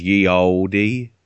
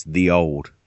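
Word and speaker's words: Ye Olde: It's not oldie. So I learned this off QI. You know, when the stuff that says ye oldie, it actually, if you want to say it properly, it's the old. It's just they wrote it completely weird.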